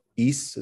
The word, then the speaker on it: Ys: Ys is how you pronounce it.